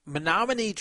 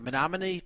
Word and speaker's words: Menominee: So the Menominee Tribe, and yes, I'm saying it right, the Menominee Tribe of Wisconsin is offering some substantial money toward a new Milwaukee Bucks arena if they can get a new casino approved.